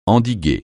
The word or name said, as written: endiguer